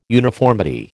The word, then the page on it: uniformity: American English